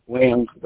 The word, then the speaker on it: Williams: Jamal Williams. – Can you spell it? – J-A-M-A-L. Williams, W-I-L-L-I-A-M-S.